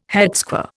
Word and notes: headsquare: Indian

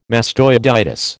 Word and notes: mastoiditis: American English

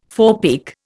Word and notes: forepeak: Indian

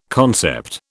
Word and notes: concept: British English

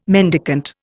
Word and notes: mendicant: Indian

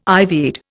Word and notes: ivied: Indian

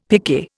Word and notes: picquet: Indian

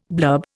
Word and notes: blurb: Indian

